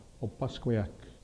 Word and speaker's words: Opaskwayak: it's Opaskwayak is the right pronunciation of it, which means a tree stand on the hill of poplar or birch.